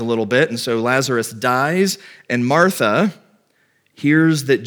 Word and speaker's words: Lazarus: Lazarus is sick, and they send word to Jesus, and they say, the one that you love is sick.